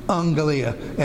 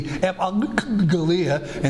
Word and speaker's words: euangelia: It would be euangelia, and so instead of saying a double G, which is hard to pronounce, they say euangelia. So they change this first G in transliteration to an N, G-E-L, and so we have ep, and then angel, and then the ending here, let's see, L-I-A over here.